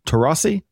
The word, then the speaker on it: Taurasi: and Diana Taurasi.